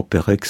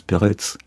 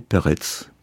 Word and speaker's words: Perec: Avec Perec, on en parlait, puisque normalement Perec se prononce Peretz. Mon père a toujours prononcé Peretz. Parce qu'en Pologne, le C se prononce C, ne se prononce pas K.